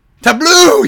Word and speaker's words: Tableau: Is it actually pronounced Tableau? – Tableau, you're my boy. – It's like table in French.